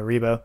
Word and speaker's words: Revo: It's spelled R-E-V-O, but it's pronounced, you know, Rebo in Japanese.